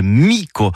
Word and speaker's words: mica: Donc ça s'écrit mica, mais c'est bien mico qu'il faut prononcer.